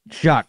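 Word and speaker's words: Shough: is it Shock or Shaw? I always forget. – Tyler pronunciation. – Shuck. Tyler Shuck, when he literally took over with the Saints, it was like a completely different team.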